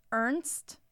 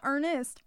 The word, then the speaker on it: Ernst: Ernst. There's no E. It's just Ernst. No, Ernest. Ernst.